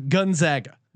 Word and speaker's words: Gonzaga: I did a deep dive on the correct pronunciation, Gonzaga.